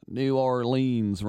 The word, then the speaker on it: New Orleans: New Orleans rhymes with beans.